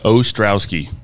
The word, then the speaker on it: Ostrowski: Ostrowski.